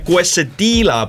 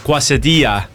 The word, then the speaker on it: quesadilla: Õpetame nüüd siin hääldama ka quesadilla. – Quesadilla. – Ei jälle jääda, quesadilla ja tortilla, mitte tortilla ja quesadilla.